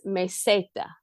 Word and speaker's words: meseta: And that's Meseta. – Meseta. Yeah. – Meseta. – The gift of Spanish is that they pronounce every single vowel. They pronounce them all. So meseta is M-E-S-E-T-A, meseta.